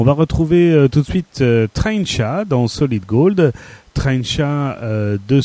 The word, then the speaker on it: Traincha: Elle s'appelle donc Trencha, ça s'écrit T-R-A-I-N-C-H-A,